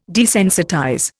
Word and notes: desensitize: Indian

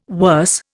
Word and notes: worse: Indian

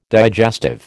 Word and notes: digestive: American English

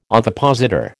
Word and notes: ovipositor: American English

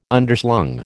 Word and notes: Underslung: American English

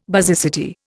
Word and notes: basicity: Indian

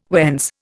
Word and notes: whence: Indian

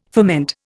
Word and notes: ferment: Indian

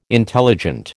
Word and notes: intelligent: American English

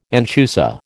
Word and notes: anchusa: American English

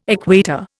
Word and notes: equator: Indian

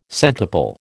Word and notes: centuple: American English

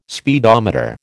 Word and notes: speedometer: American English

